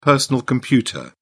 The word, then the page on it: personal computer: British English